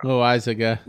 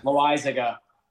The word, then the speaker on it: Loaisiga: It sounds like lasagna, but I know it's not lasagna. – Loaisiga. – Loaisiga.